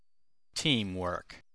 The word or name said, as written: teamwork